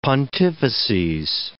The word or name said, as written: pontifices